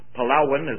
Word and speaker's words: Palawan: Dana and Kelly Danielson in the Philippines on the island of Palawan, or Palawan, as they pronounce it, somewhere out of Brooks Point.